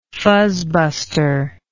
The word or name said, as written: fuzzbuster